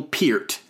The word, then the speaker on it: Peart: Alex Lifeson on electric and acoustic guitars, and Neil Peart, and that is how you pronounce it, on drums and percussion.